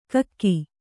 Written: ಗುಕ್ಕ

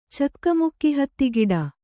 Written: ಚಕ್ಕಮುಕ್ಕಿಹತ್ತಿಗಿಡ

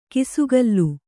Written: ಕಿಸುಗಲ್ಲು

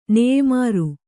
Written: ನೇಯ್ಮಾರು

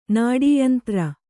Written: ನಾಡೀಯಂತ್ರ

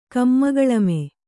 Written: ಕಮ್ಮಗಳಮೆ

